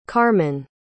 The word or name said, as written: carmen